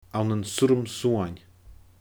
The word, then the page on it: ann an surram suain: Scottish Gaelic